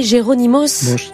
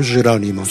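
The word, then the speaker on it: Jerónimos: Et quand on parle, donc, je l'ai mal prononcé tout à l'heure, mais Monasterio de Jeronimos. – Monasterio de Jeronimos. – C'est ça.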